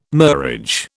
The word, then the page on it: murage: British English